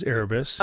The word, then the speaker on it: Erebus: It's Terebus, but, it's proper pronunciation of Erebus is Erebus. – Okay. – Not Erebus.